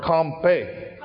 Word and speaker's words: kanpe: We would spell it V-E-E-D. They spell it V-I-D. Sock vide. Pa. Like papa, only one. Pa. This is a little harder. Compay. K-A-N-P-E.